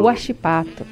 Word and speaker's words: Huachipato: Huachipato. – Huachipato, claro que sim.